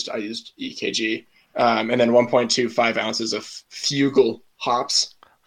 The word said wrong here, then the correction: Fuggle: I'm British. I'm going to correct you on your pronunciation, but yeah, Fuggle.